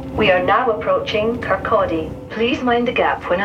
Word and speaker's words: Kirkcaldy: So you have to pronounce it Kirkcaldy. Kirkcaldy.